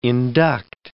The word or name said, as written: induct